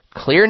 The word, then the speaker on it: Xlear: And that's spelled X-L-E-A-R, clear nasal spray.